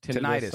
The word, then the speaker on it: tinnitus: I think it's pronounced tinnitus. Some people say tinnitus.